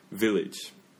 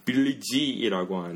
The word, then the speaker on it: village: village. 즈 즈예요. 음. 끝나는 마지막에 음. 지는 아닙니다. – 아. – 물론 맞춤법 보면은 G E로 끝나지만 음. – 빌리 지이라고 안 해요.